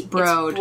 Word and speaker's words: Broad: The nice thing about, and I hope I'm pronouncing this right, because it's spelt like broad, but apparently it's Broad.